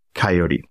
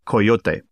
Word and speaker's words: coyote: Coyote. But pronounced the correct way, Coyote.